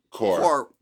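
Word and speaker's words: Corps: The song is Corpse. – Core. – Okay, so I guess we're not pronouncing the P or the S. – No P. – No S, just core. – It means core.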